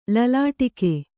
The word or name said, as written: ಲಲಾಟಕ